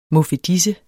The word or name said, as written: muffedise